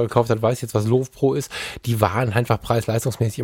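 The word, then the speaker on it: Lowepro: Ähm, ich habe ja lebenlang Love Pro benutzt. Love, Lö, wie, wie spricht man es aus? Weißt du das? Low Pro.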